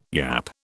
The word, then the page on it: yap: British English